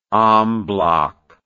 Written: en bloc

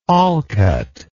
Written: Olcott

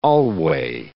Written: alway

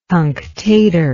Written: cunctatorship

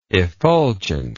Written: effulgently

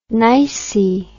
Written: nicy

